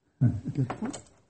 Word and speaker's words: Gipfel: Gipfel.